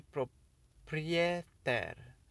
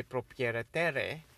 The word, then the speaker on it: propriétaire: I said propriétaire. Yes, I pronounced the R, but not the E. I didn't say proprietaire.